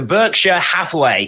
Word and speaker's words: Berkshire Hathaway: So the, if I pronounce this correctly, the Berkshire Hathaway, is that how you say it? – Yes, correct.